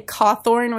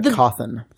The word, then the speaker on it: Cawthon: I, I keep wanting to say Cawthorn, which is like reverse Rhode Island way of pronouncing his name. – It's uh, Cawthon. Cawthon, sorry.